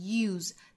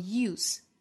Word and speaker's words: use: 那当然很多老派的英国人也会把 F-U-L-L 读成 full, 我们知道这样的情况就可以了。I am putting myself to the fullest possible use, use 这个词做动词的时候读成 use, 单词尾部是浊音的 z, use, 那做名词的时候读成 use, use, 单词尾部是清辅音。